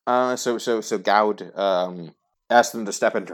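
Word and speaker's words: Gawd: And it seems to be effectively led by Gaud. Or I'm going to pronounce it Gaud, because it's G-A-W-D.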